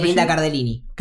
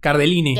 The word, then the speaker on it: Cardellini: Linda Cardellini. ¿Cómo se dice? Sí, Linda Cardellini. – Cardellini.